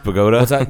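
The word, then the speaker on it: Bogotá: It's pronounced Bogota.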